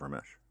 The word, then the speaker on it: Ramesh: So the pressing question is, what is the correct pronunciation of Ramesh? – Well, my parents named me Ramesh. Uh-huh. That is the classical pronunciation of those letters.